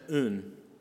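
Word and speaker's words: un (οὖν): Now, therefore in the Greek is this little word that I would probably mispronounce, pronounced un. If you use English letters, it would be O-U-N.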